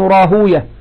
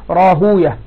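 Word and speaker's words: راهويه: قال شيخنا أبو الفضل ابن العراقي رحمه الله: فممن سمع منه قبل اختلاطه أحمد بن حنبل وإسحاق ابن راهوية أو راهوي، هكذا ينطقها أهل الحديث راهوي، وينطقها أهل اللغة راهوية، وهكذا في كل اسم يختم بويه.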